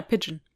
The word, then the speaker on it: pigeon: The pit ich kann das Wort Taube heißt der Pigeon oder wie spricht man das aus? – Ja, Pigeon.